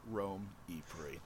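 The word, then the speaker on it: Rome E-Prix: And we have the Romy Prix. – Rome? – It's going to be a busy weekend. – No, the Formula E Rome E Prix. – Oh. – It's very important how you pronounce it, apparently.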